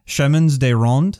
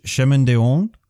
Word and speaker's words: chemins de ronde: Chemins de ronde, the clearly French term, which I think is actually pronounced chemin de ronde. Allure, spelled A-L-U-R-E.